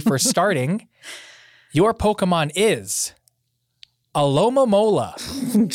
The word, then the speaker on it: Alomomola: Alomomola. – Alomomola.